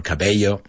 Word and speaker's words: Cabello: The birthday cake is for George Miller, Camila Cabello, or Cabello, this is the real way to pronounce it, and Jessica Biel.